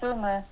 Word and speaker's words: Sumy: Tell me about how you're feeling at this time, you know, about your family you had to leave behind in Sumy. Am I saying that right? Sumy? – Yeah, it's Sumy.